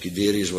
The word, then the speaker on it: Guiderius: Bovendien een koning, ja, hoe moet ik dat nou maar uitspreken? Guiderius, G-U-I-Derius.